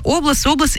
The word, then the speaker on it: область: область, и я, знаете, как легко запомнить, вот есть облака, а области, это обратное ударение.